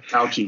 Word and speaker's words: Fauci: This Fucci guy, the little short guy. Fauci. I can't think of how he pronounced his name. Yeah, Fauci. But he's good.